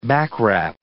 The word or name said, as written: backwrap